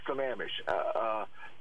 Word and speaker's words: Sammamish: No, Lake Sammamish. There's a lady that used in one of these documentaries, and she called it Lake Sammamish. – Yeah, what? – She didn't know how to pronounce it.